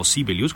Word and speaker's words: Sibelius: A Jean Sibelius, o Sibelius como se pronuncia en Finlandia, le debe este país su primera gran aparición en la historia de la música.